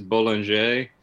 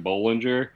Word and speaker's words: Bollinger: I believe the correct pronunciation is Bollinger, but I feel much more comfortable saying Bollinger.